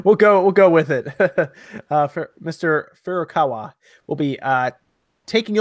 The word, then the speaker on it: Furukawa: And I always get his last name wrong. It's Furukawa. Help me out here, Sean. Is that I always mispronounce it? – I think it's like Furukawa. – I have to like contort my face to say it just right. – I think it's Furukawa.